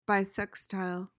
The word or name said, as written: bissextile